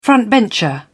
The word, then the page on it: frontbencher: British English